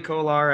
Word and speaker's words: Kolar: Is it Charlie Kolar next? – It is Charlie Kolar, yeah. – All right. Charlie Kolar at 67 overall.